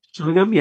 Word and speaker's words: Chillingham: We're going to dive into Chillingham Did I say that right? Chillingham? – Chillingham, yeah, that's right.